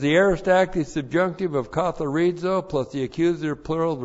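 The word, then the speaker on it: katharizo (καθαρίζω): All right, the verb katharizo. Looks like that in the Greek, K-A-T-H-A-R-I-Z, long O, katharizo.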